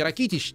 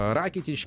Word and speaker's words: Ракитич: Естественно, Лука Модрич, Иван Ракитич, как и известны хорватские имена, э, вернее, имена и фамилии, да, здесь, как правило, ударение на первый слог, поэтому не Ракитич, не Ковачич, там, не Брозович или там э, Манжукич, а Ракитич, Кова... Ковачич, Брозович и Манжукич.